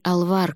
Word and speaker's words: Алвар: Пыталась искать в сети их имена, но если Миролюбы и Родимы еще попадались, то единственный Алвар, которого удалось найти, оказался финским архитектором, родившимся в конце XIX века. К тому же ударение в его имени падало на первый слог.